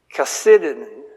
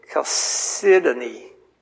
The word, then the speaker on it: Chalcedony: I have the pronunciation spelled out here. Chasidin. The accent is on the second syllable. Chalcedony, I believe is how they pronounce it.